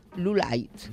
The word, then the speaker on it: Lolite: Y en español, pues procede del pueblo de Lolite, y entre paréntesis te pone, se pronuncia Lulite.